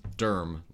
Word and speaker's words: Durham: But it reminded me of, you know, you'll hear people say the city of Durham as Durham. Like, it's D-U-R-M.